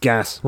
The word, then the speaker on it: geas: Like, what is G-E-A-S? – It's like gas. It's pronounced gas.